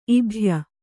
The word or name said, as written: ಇಬ್ಬಾಯ